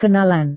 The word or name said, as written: kenalan